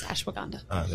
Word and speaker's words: ashwagandha: I will pronounce it for you. It's ashwagandha.